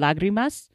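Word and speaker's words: lágrimas: And he puts the emphasis on the wrong syllable in lagrimas, and which so that like bugs me. But like, and maybe we're just all saying it wrong because he was there when the word was written. – Well, but the accent is on the first syllable visibly, so that is why I'm just like it's off.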